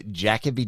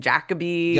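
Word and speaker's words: Jacobi: Like I thought it was Jacoby, and then I keep hearing people saying Jacoby. – Yeah, well, that's the more like, so the fame, obviously famous international actor, but very famous Shakespearean actor, Derek Jacoby, pronounces his name Jacoby.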